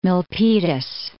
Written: Milpitas

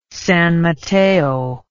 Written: San Mateo

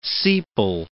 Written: sepal

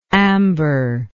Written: amberous

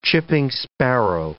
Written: chipping sparrow

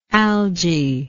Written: algie

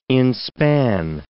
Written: inspan

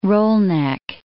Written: roll-neck

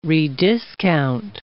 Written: rediscount

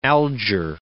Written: Alger

